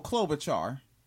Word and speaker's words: Klobuchar: So he was feeling out, how do you say her name? Klobuchar? – Oh, Klobuchar.